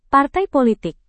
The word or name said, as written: partai politik